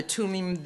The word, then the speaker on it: Thummim: Okay, so this is the Lux Veritas, right, light and truth, Urim and Tumim, or Thummim, as they say in up in New Haven, the Urim and the Thummim. Which is annoying, and they don't even know what it is, but whatever, that's how they pronounce it, because that's what they are.